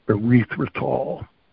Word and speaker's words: erythritol: Anesthetol? – Erythritol. – Erythritol. Erythritol. Okay. – Yep. It's spelled E-R-Y-T-H-R-I-T-O-L.